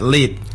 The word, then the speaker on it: lit: So the sound seems like I don't know how to explain it, but sometimes you feel like the L, it's like more down here. So you just take it from down here like lit.